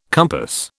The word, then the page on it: compass: British English